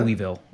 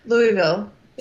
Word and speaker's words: Louisville: Louisville. I don't know how you say it. Kentucky. – Louisville. Yeah. – So I said Louisville because I'm an idiot.